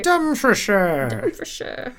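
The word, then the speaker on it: Dumfriesshire: Dumfrieshire. What I hope name. I'm saying that right, or even remotely right. – Dumfrieshire. Close enough.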